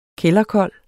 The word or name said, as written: kælderkold